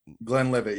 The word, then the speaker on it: Glenlivet: And a friend of mine brought in a bottle of 15-year, I think it was Glenlivet. – Yeah. – I could be mispronouncing the name. I'm Glenlivet.